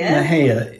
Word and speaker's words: Mexia: Mahia. It's spelled M-E-X-I-A. – For probably half the time, at least, that we lived in Texas, I thought it was Mexia.